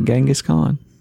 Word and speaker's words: Genghis Khan: Oh, you said it correctly. Genghis Khan. Instead of Genghis.